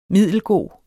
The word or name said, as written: middelgod